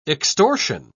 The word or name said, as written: extortion